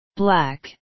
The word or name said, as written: black